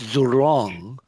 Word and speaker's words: Zhurong: And let me get the pronunciation correct here. It's Zhurong, a traditional fire god.